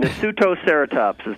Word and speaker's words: Nasutoceratops: Is it Nasutoceratops? – Pretty good. Nasutoceratops is the way we say it.